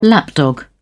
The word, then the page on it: lapdog: British English